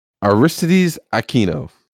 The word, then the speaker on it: Aristides Aquino: I'm going to butcher this fucking name, and I love it. – Can't wait. – I went with Aristides Aquino. – Oh, I think you nailed it.